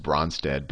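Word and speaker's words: Brønsted: I always say just Bronsted, but if you don't have fancy fonts, it's spelled like this, Bronsted-Lowry acid or base, and I'll say, but if you have good fonts, there's usually this little cross in the O, so Bronsted.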